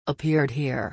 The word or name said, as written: appeared here